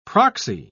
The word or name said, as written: proxy